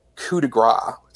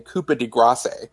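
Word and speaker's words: coup de grâce: One, it's a lyric which is, he mispronounces deliberately coup de grace, so saying coupe de grace,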